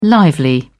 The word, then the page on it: lively: British English